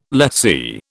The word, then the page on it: lessee: British English